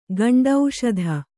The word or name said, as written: ಗಂಡೌಷಧ